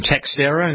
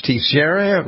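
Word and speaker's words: Teixeira: I was pronouncing the name Texera. No, it's Tex, it's Texera.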